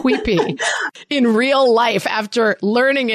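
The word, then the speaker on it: quipu: And you talked about, I believe it's the Quipi, is that's how it's pronounced? – Oh, yeah, yeah, yeah.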